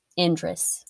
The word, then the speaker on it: Endres: I just listened so I could pronounce this right, and I knew I was going to botch it. Endres.